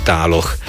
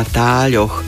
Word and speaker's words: táľoch: To znamená, že aj v tvaroch pri skloňovaní používame meké ľ, čiže tále, i keď v bežnej reči málo kto, i keď mnoho používateľov jazyka určite áno, vyslovuje to ľe pekne meko, ale v ďalších tvaroch už musíme zapísať meké ľ s mekčeňom, čiže správne je na táľoch, podobne aj v ostatných tvaroch.